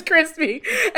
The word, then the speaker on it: Chrispy: And my name is Crispy. And that's spelled C-H-R-I-S-P-Y.